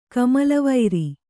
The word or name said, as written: ಕಮಲವೈರಿ